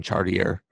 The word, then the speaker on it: Chartier: But right now, the New England Cartel, head coached by Tyson Chartier, because it's a French name, but he pronounces it with the hard R.